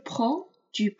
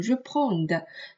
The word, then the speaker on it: prends: 在这里我们需要注意的是，je prends，tu prends。千万不要把这个的发出来啊，不要读成 reprend，所以这个发音上需要注意一下。